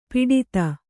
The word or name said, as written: ಪುಟಿತ